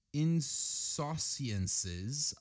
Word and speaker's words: insouciance: Insouciousness. – Yes, that is exactly what I just said. Insouciousness. – You gotta spell it for me then. – Insouciance. – Ooh, it sounds French. That was very French.